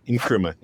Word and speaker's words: Nkrumah: Nkrumah, yeah.